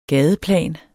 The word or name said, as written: gadeplan